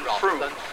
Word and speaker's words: Frome: The possibilities for misunderstanding are multiplied by the fact that it is spelled F-R-O-M-E. So to the uninitiated, this is quite clearly pronounced Fro, as in row, rather than Froom, as in boom.